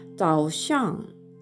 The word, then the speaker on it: 早上: ザオシャンと 3 世 4 世の組み合わせで発音するかザオシャンというふうに、えー、 3 声の後の形成として発音するかになります。